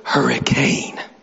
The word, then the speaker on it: Hurricane: It's spelled hurricane, but it's pronounced hurricane.